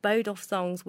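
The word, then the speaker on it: Boduf Songs: And we've got Bodoff Songs with us, and I'm actually pronouncing it right, because even though it's spelled B-O-D-U-F, it's pronounced B-O-W-D-U-F, if that makes sense to you.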